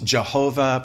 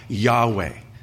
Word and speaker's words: Yahweh: It's referred to as the Tetragrammaton, the four consonants, YHWH, most popularly pronounced Jehovah, probably most accurately Yahweh.